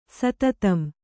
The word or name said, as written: ಸತತಂ